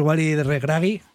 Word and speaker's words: Walid Regragui: Y a Llorente hay que decirlo: el técnico de Marruecos, Walid Regragui, creo que se pronuncia así, sí.